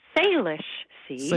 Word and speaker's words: Salish: Salish Sea. – Salish Sea. I think that's an indigenous word that's renamed what was the Straits of Georgia. – Right. – So it's the Salish Sea, but it is something that I love doing and have kept up doing even despite cooler temperatures recently.